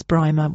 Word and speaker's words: Brymer: It might be Brimmer, but I'm saying it's Brimer with B-R-Y-M-E-R Jones.